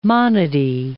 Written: monody

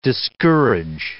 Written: discourage

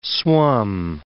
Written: swum

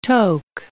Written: toke

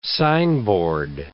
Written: signboard

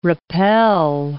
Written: rappel

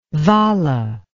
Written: vala

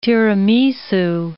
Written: tiramisu

